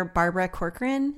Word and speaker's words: Barbara Corcoran: I also admire Barbara Corcoran. Is that how you say her name? – Yes.